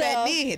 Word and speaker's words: penne: It's so, penne. That's how you say it.